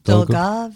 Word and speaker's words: Dolgov: Dolgov.